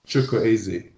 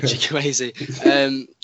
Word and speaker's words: Chukwueze: Chukwueze. – Yeah, Chukwueze.